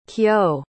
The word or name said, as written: kyo